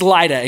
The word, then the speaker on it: Lydda: So it's Lydda, in case you guys were wondering. You don't have to Google it. I did it for you. Peter is nearby at Lydda.